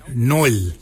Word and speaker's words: nyooly: Nai is spelled N-G-A-R-L-Y. Nail for underarm. For your elbow, we say noil. Noil is spelled N-Y-O-O-L-Y.